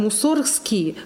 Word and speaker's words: Мусоргский: Поэтому Мусоргский настаивал, например, на том, чтобы его фамилия произносилась как Мусоргский, чтобы было тоже корень Мусорга, муза, а не мусор.